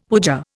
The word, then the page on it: puja: Indian